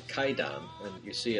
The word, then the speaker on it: Kwaidan: But it's K-W-A-I-D-A-N, but that's pronounced Kaidan.